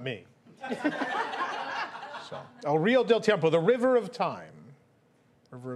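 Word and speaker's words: El Río del Tiempo: El River de Tiempo. – I'm gonna give it to you. It's El Rio del Tiempo. El Rio del Tiempo.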